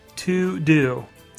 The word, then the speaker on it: TeuxDeux: Now, I need to spell it for you because it's not how it sounds. Uh, it's actually, I believe, a French word if my memory serves me correctly, but it is spelled out T E U X D E U X.